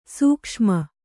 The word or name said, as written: ಸೂಕ್ಷುಮ